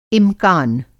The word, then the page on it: इमकान: امکان